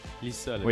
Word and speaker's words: Lisa Leblanc: By the way, on n'avait pas dit Lisa Leblanc, faut prononcer Lisa. Lisa Leblanc.